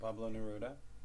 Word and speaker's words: Pablo Neruda: I quoted Pablo Neruda on that one. Did I say it wrong? – No, he's Pablo Neruda.